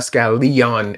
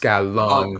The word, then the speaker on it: Scallon: It's not Scallion. It's Scallon.